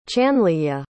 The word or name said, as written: chanlyeya